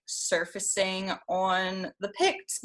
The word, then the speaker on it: Picts: How did you come across, how did you say it, the Picton? – The Picts.